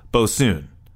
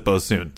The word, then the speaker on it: boatswain: Say it with me, folks, and then he spelled it out for us. Bosun. Bosun. So I guess we were saying it wrong. Introvert says we should pronounce it Bosoon.